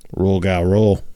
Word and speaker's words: Rougarou: I want to cover the Rougarou. – Rougarou.